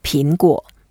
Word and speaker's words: 苹果: 苹果. Yeah, there are only two words, and 果 means the fruit. And 苹, that's the description of this type of fruit. 苹果.